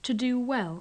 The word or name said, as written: to do well